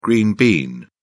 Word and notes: green bean: British English

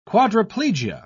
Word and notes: quadriplegia: British English

